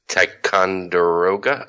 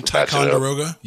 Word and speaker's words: Ticonderoga: So you're saying Ticonderoga? – Yes, sir. – You said it right the first time. – Ticonderoga? – Yeah. – Oh, that's exactly how you say that.